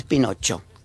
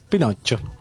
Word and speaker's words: Pinocchio: Si se escribe creo que con doble C. – Entonces es Pinocho. – Pinocho.